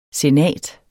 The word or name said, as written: senat